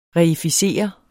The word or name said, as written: reificere